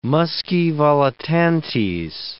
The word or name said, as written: muscae volitantes